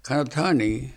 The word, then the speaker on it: Kanadani: It was spelled Kanadani, but Kanatani.